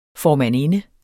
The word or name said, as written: formandinde